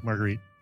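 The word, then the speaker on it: margherita: And then pizza margheriti. I think I'm saying that right. – No, margarita. Is it margarita? It's like pizza margarita, usually.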